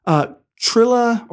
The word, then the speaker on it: Trillia: Trilla, or I always say her name wrong, but T-R-I-L-L-I-A, right? – Yes.